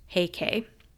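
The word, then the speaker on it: Heike: The Minamoto, alternately read as Genji, as in the Tale of Genji, and the Taira, alternately pronounced Heikei.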